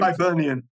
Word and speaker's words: Hibernian: I'm not too sure I'm going to butcher this one, but Herbian Football Club. Then going Hibernian.